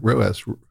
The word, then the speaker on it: ROAS: Is that how it's pronounced? – Yeah. ROAS, return on ad spending.